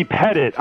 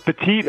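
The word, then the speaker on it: Pettitte: For those confused, his name is pronounced Andy Pettit. I always thought it was Petit as well.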